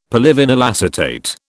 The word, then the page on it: polyvinyl acetate: British English